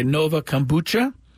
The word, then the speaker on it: Nova Kombucha: Be there for weekend promotions like the Padres House Band presented by Nova Kombucha. I guess that's how you pronounce it. – Yes, you got it right.